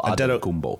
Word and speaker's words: Antetokounmpo: Antetokounmpo. Yeah, you emphasize it's more the, you said it right, but it's more the emphasis of the syllable. Antetokounmpo.